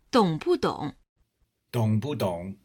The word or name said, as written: dong bu dong